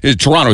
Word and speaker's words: Toronto: Toronto, same thing. If you hear somebody pronounce that second T, you know.